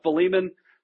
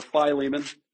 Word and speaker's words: Philemon: Go to Philemon 24. Or Philemon. Tasha's always good to help me out with the pronunciation there.